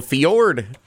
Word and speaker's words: fjord: Like a fjord. – Yeah, I do love the silent J.